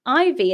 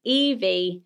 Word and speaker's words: Ivy: Ivy is not a common name here, but it's often pronounced Evie.